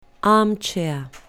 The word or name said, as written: armchair